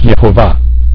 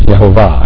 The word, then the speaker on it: Yehovah: The word there is Yud-Heh-Vav-Heh, and which is pronounced Yehovah. I pronounce it Yehovah.